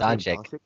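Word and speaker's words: Dončić: Doncic.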